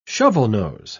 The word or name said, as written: shovelnose